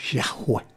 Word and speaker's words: Yahweh: YHWH que se pronuncia Yahweh.